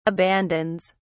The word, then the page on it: abandons: British English